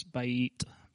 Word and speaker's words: bayit: In 11, he's talking about here, 11b, and if you want to know the Hebrew word, it's bayit, B-A-Y-I-T.